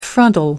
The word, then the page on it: frontal: American English